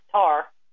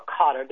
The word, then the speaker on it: Qatar: Now, Qatar, or Qatar, depending on how you choose to pronounce it, is stationed right across from Iran.